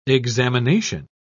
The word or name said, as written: examination